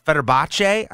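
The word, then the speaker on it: Fenerbahçe: So he plays for this Turkish Super League team, Fener- Fenerbahce, I think is how it's pronounced.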